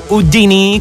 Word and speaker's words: Houdini: come in questo caso con Dua Lipa e la sua Houdini, o Houdini come la pronuncia lei, che dagli States magicamente, in un battito di ciglia, ritorniamo in Italia.